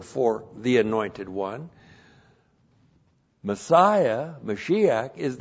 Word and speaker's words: Mashiach: Mashiach.